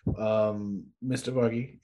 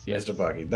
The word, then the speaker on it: Mystapaki: Mystapaki, that's how you say it, right? – Yes. – Mystapaki.